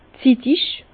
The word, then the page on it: zittig: Bas-Rhin; Herrlisheim